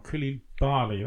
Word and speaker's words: Koulibaly: Koulibaly, that's it.